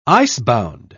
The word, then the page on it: icebound: British English